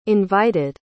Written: invited

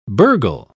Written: burgle

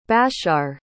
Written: bashshar

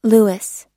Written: louis

